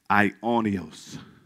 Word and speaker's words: aionios: Aionios is spelled A-I-O-N-I-O-S, but it's pronounced aionios.